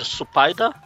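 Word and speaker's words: Supaida (スパイダー): Então, Spider-Man, como não tem, o S vira o Su, aí vira Supaida, e como não tem o R sozinho, alonga o A, no final fica Supaida, Man.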